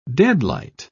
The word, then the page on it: deadlight: British English